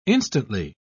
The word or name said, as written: instantly